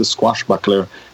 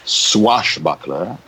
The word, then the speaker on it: swashbuckler: the squashbuckler diaries it's not swashbuckler it's the way she mispronounces it and oh.